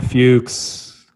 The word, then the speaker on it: Fuchs: Allan Fuchs. – I still don't even know how to pronounce my last name. What do you like better, Fuchs or Fuchs? – Fuchs.